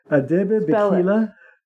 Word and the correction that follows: Abebe Bikila: Abebe Bikila. – Spell it. – A-B-B-E-B and is B-I-K-I-L. – He's an Ethiopian marathon runner.